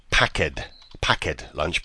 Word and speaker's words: packed lunch: Packet lunch. – Very good. Just change the pronunciation a little bit. It's not packed lunch, but packed lunch.